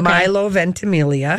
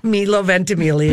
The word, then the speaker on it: Milo Ventimiglia: Milo Ventimiglia. – Okay. – Okay. – Milo. – Milo Ventimiglia.